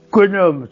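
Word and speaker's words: Quenum: il faut bien prononcer le mot konom. C'est un nom fermé et non pas un nom avec accent.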